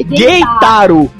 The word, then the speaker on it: Keitaro: Mas enfim, o Keitaro, que é o personagem principal... – Não, calma aí. Ó, vamos lá que a gente gosta de uma pronúncia certa aqui no United Cast. É Geitaro.